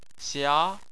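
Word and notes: xia: tone 2

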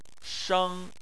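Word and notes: sheng: tone 1

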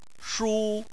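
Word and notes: shu: tone 1

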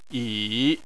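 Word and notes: yi: tone 3

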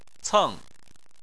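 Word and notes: ceng: tone 4

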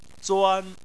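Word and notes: zuan: tone 1

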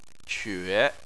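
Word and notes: que: tone 3

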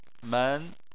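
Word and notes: men: tone 2

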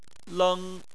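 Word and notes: leng: tone 1

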